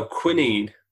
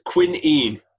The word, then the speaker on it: quinine: No, that's quinine. That's the pronunciation according to Wikipedia.